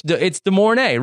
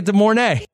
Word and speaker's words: De Mornay: No, it's De Mornay, Rebecca De Mornay.